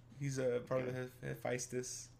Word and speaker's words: Hephaestus: Hephaestus? – Hephaestus? Hephaestus, yeah.